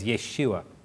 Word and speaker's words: Yeshua: The correct pronunciation of Jesus' name in Hebrew, by the way, is Yeshua.